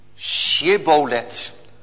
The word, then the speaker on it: Shibboleth: Het woord Shibboleth. En als ze dan die CH-klank, bij ons is dat de J-klank, Shibboleth, als ze die niet konden uitspreken, dan was het duidelijk dat het toch een Efraïmiet was.